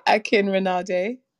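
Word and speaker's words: Akinrinade: I can't say your last name, but I'm going to spell it. – That's okay. Akin Rinade. – Akin Rinade. Okay, so it's A-K-I-N-R-I-N-A-D-E. – N-A-D-E.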